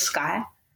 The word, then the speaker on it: Skye: And I guess it's because the E's at the end, they feel like they have to pronounce that. And I'm like, my name's just, it's just Skye.